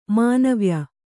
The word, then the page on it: ಮಾಣವ್ಯ: māṇavya